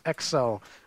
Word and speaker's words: XEL: It's spelled XEL, but it's pronounced Excel, like the Microsoft program.